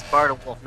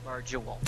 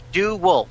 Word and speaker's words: Barduwulf: Okay, and it's Barduwulf, not Barduwulf.